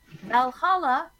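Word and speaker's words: VA-11 Hall-A: On August the 7th, we were talking about Valhalla or VA11 Hall A. That's how it's spelled, guys.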